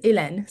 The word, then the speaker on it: Hélène: And my name is pronounced Hélène, so the H is silent. So the closest way to pronounce it from an English perspective would be Ellen, like Ellen DeGeneres.